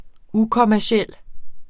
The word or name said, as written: ukommerciel